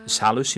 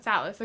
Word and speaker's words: Salus: And how will this, both in partnership with the, the SEALs? – Salus, you mean? – Salus, okay, I didn't know how to say it.